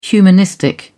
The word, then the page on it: humanistic: British English